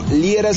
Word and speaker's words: Liera: Recuerda, todas mis redes me encuentras por mi nombre, Fernando Liera. Fernando Liera se escribe L-I-E-R-A.